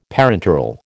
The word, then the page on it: parenteral: American English